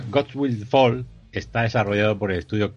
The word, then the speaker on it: Gods Will Fall: Y vamos a ver si de verdad pues ha, valido la pena y ha salido un gran juego como es, eh, a ver si lo digo bien, Gods Will Fall, ¿no? Sí, señor, abajo con los dioses.